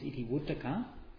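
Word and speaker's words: Itivuttaka: I'll just say, go back to the Iti Yukta is the Sanskrit pronunciation. In Pali is Iti Vuttaka.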